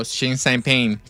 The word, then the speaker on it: Xi Jinping: ¿Champán? – No, Xi Jinping.